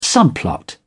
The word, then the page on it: subplot: British English